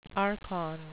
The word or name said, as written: archon